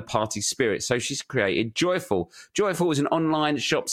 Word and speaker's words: JYFL: So I thought my friend Jesse's new small business, JYFL, which is pronounced joyful, but spelled JYFL, might be of interest to him and also all the listeners who are sober or pregnant.